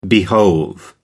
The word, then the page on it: behove: American English